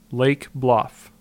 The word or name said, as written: Lake Bluff